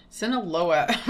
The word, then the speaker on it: Sinaloa: Sinaloa? – Sinaloa wren.